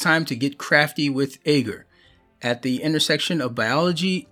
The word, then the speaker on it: agar: A-G-A-R. Let's see. How am I supposed to pronounce this? Agar art. So survey says agar. Okay, right on.